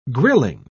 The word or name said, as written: grilling